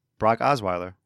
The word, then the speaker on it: Brock Osweiler: You said Brock Wilder. – Brock Osweiler. – I don't know if he said Brockwile. You said Brosswile. You're saying his name wrong.